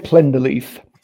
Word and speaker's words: Plenderleith: So Ian Plenderleith.